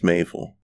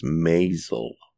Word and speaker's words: Maisel: The Marvelous Miss Maisel. – I've heard of it, never watched it. – Oh, I just pronounced it wrong. The Marvelous Miss Maisel.